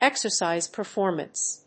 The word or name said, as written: exercise performance